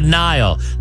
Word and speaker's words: Niall: All right, listen, you want to text in the word Nile. Now, it's spelled goofy, okay? N-I-A-L-L. Want to text in the word Nile. N-I-A-L-L.